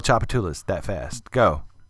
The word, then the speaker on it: Tchoupitoulas: Chapatulas, can you spell it? – Hell no, I can't spell Chapatulas. – T-C-H-O-U-P-I-T-O-U-L-A-S.